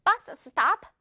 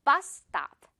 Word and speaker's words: bus stop: It's not bus stop. 열심히 스스 중간에 두 번이나 하지 말아요. bus stop.